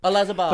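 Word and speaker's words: Olazábal: Or as many would say, Olazabal. – Olazabal. – But you've got the correct pronunciation there.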